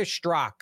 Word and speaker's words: Strzok: Like, he was removed from Peter Stork. I think the Z is silent or silent. Peter Strzok. I'm sorry. Peter Strzok is his name. That's how you say his name.